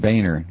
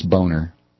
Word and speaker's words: Boehner: You know, somebody emailed me and said his name is pronounced Boehner. No, it's not. It's Boner.